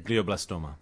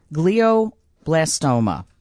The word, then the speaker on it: glioblastoma: And it turned out that that's when they found this, how do you say it? – Glioblastoma. – Glioblastoma.